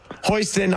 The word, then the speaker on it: Hostin: Let's get right into a sexual assault case covered by Sonny Hostin on The View.